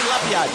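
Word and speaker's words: Labyad: Tem uns caras que falam Labiá. A pronúncia é Labiade ou Labiá, hein? – Marroquinamente falando, Marroquinamente. – Labiá. Ah, meu Deus. – Portuguesamente, Labiade. – Então tá bom. Vamos no português, né? Labiade.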